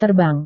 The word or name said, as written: terbang